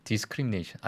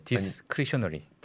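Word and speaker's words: discretionary: 디스크리미네이션 아니야 디스크리셔너리.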